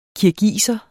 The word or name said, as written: kirgiser